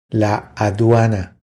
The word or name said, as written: La aduana